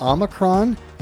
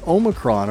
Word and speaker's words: Omicron: Now, officially, almost anything works. Both Omicron and Omicron are fine. The Oxford Dictionary includes Omicron. And in classical Greek, it was pronounced with the second syllable sounding like me.